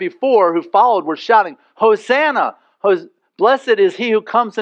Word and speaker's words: Hosanna: And they're saying, Hosanna is the way you would properly pronounce it.